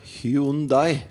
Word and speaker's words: Hyundai: Ja, Det uttaler vel Hyundai. Hvordan er det det uttales? Hyundai. – Hyundai.